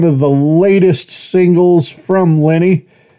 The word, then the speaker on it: Lenne: spelled L-E-N-N-E, pronounce it Lynn or Lenny. They prefer Lenny, so that's what I'm going to use.